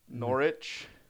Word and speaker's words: Norwich: I'm paying attention to my game, which it turns out is Tottenham and Norwich. – Norwich. Oh, Norwich. I thought you said Norwich, like porridge, but with an N. – No, but that is kind of how they pronounce it, like Norwich.